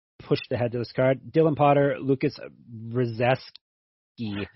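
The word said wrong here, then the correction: Brzeziński: Your pronunciation of Polish names always gets me. It's Brzezinski.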